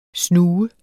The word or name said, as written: SNUEDES